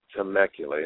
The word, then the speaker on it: Temecula: Temecula, yeah, that's the way they pronounce it.